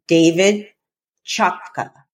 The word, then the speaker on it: David Chotka: To have this discussion, I've invited my very special guest, the Reverend Dr. David Chotka.